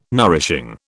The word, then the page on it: nourishing: British English